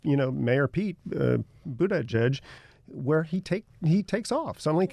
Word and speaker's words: Buttigieg: Well, Buttigieg. That's all you have to remember. Buttigieg.